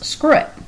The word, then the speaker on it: SKRWT: So it's capital S-K-R-W-T, and it is pronounced Scroot.